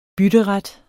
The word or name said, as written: bytteret